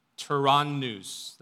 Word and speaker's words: Tyrannus: In Korean, or rather in Greek, it's actually read Tyrannu without the S. The Koreans pronounce it as Tyranno, right? I was actually surprised to learn that. It's Tyranno.